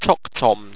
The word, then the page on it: toctomn: owstwecian